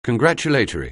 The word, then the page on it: congratulatory: anglais